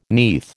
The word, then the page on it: neath: American English